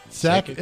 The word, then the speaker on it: Sakic: Psychic. – Sakic. S-A-K-I-C. I don't know. I don't follow hockey. Unless it's the Predators. Joe Sakic? Did I say his name wrong? I probably 34. – Did. I don't care. – No. I think you got it right.